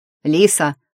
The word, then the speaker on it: Лиса: «Лиса!» — тут услышала оклик с ударением на «и».